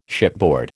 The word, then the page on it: shipboard: American English